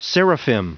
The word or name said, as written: seraphim